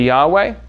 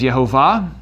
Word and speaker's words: Yahweh: There are different ways to say it. Most people say Yahweh. Another way to pronounce it is Yehovah. that some scholars think that that's a more accurate pronunciation. But I typically just say Yahweh because it's kind of like the majority view, but also recognizing that could be wrong.